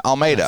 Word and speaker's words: Almeida: I'm at the home of Joseph and Kirsten Almeida.